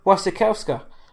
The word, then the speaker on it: Wasikowska: And finally, as did Mia Wasikowska. I hope I'm saying that right, because I think you did, man, and I always butcher her name.